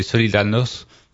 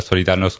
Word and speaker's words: Solidarność: Pour rappel, Solidarność, on prononce ça Solidarność en français, mais Solidarność pour les pays de l'Est,